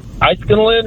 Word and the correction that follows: Inniskillin: or something like that? Insulin? – Well, I mean, if you're going to chug insulin, what happens in Vegas and so forth? Inniskillin. Close enough.